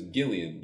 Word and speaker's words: Gillian: Like Gillian Jacobs is Gillian. – Yeah. – As opposed to Gillian. – I'm used to my hard G's.